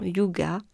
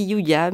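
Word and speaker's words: hygge: L'été dernier, j'ai lu un livre qui s'appelle euh, Mission Yoga alors excuse-moi du pronom, de la prononciation, je, crois que ça se dit Yuga, Mission Yuga, euh, c'est le, le truc euh, danois euh, pour se sentir bien, de bien-être.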